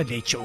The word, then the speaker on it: Leite: Carmen Lucia Leice, Leite daha doğrusu Leice. Ee, da Leice okunur e, Rio aksanında.